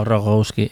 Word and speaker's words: Rogowski: Es que Rogowski, eso, Rogowski.